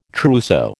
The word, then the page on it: trousseau: American English